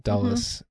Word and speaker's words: Dulles: Going back to Alan Dulles, or however you pronounce his last name. – Dulles.